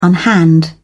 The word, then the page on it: unhand: British English